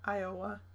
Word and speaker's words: Iowa: Iowa.